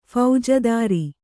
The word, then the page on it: ಫೌಜದಾರ: Kannada